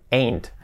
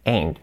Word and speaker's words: ain't: La pronunciación ain't. Se escribe ain't, pronunciación ain't. Ain't.